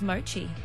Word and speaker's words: mochi: That was mochi.